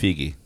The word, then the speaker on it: Feige: It's pronounced Figi. – Exactly. It's Gal Gadot. You're right. – I had been... I'm guilty of that as well. – With those two, it's Gadot. – No, it's not. – I don't care what Gal Gadot thinks. – I don't care what she thinks. – Okay, it's Gadot. She doesn't know how to say her own name. That's correct. Kevin Feige doesn't know how to say his own name. – Figi can say his name right. He says Figi.